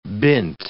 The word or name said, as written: bint